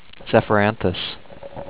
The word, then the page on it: Zephyranthes: ze-fer-AN-thes